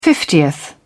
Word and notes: fiftieth: British English

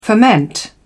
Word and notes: ferment: British English

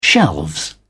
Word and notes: shelves: British English

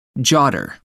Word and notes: jotter: American English

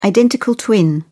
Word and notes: identical twin: British English

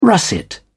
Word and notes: russet: British English

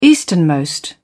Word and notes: easternmost: British English

